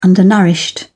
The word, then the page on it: undernourished: British English